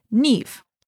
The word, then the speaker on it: Niamh: So Niamh is spelled N-I-A-M-H. If you are ever wondering, if you see that in the book, it is actually pronounced Niamh.